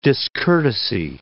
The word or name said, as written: discourtesy